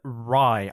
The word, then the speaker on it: Rhye: It's like rye bread. Um, yeah. it's not spelled like that, though. It's R H Y E.